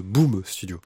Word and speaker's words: Boom Studio: Non, pas Boobs, Boom Studio. Ah, Boom. Voilà.